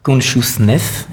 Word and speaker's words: Consciousness: Je vous laisse en musique avec un de vos titres, Consciousness. Est-ce que je prononce bien? – Parfait.